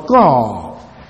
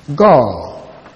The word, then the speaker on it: gur: The actual Hebrew word is the word gor. Gor. G-U-R. Gor. Or some spell it as G-U-W-R.